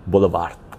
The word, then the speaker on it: Bolívar: You pronounce it like Bolívar. It's more like Bolívar, Bolívar, Bolívar. I don't speak Spanish, so I do the Chicho accent. Bolívar. Wind, wind. Soft V, soft V.